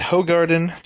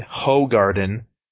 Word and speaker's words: Hoegaarden: I find Hoegaarden. Did you know that they tell you how to pronounce that on their bottle? Yeah, they say it's Hoegaarden. They're like, it's Hoegaarden, you motherfucker. Stop saying Hoegaarden.